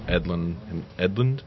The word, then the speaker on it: Edlund: A bunch of different artists contributed to it, including there's a couple of new pages from Ben Edlund. I think is how you say his name.